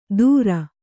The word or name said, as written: ಧುರ